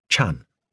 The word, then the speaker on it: Chan: In modern Chinese, the character for Zen is pronounced Chan.